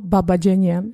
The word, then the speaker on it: Babajanian: Arno Baba Janjan Musikpädagogisches College. – Genau. Arno Baba Babajanjan. – Okay, richtig ausgesprochen. Sagst du es nochmal bitte? Arno Babajanian.